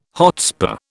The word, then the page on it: hotspur: British English